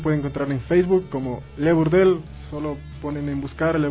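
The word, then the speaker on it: Le Bourdel: Les voy a más o menos a pronunciar cómo se escribe Le Burdel. Le Burdel. B-O-U-R-D-E-L.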